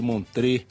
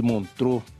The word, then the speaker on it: Montreux: Já participou do Festival de Montreux, ou de Montreux, como queiram aí pronunciar, diversas vezes.